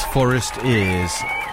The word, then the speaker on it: Forestears: I think, actually, I mispronounced them and called them forced ears. I think it's forest ears. Well, or it could be forest ears. It's like forest ears, foresters, only with an A in it. For, F-O-R-E-S-T-E-A-R-S, if you want to get on Bandcamp.